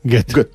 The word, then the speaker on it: Goethe: Y según he visto, se pronunciaría Goethe. – Goethe. – Mira, bueno, como Gota.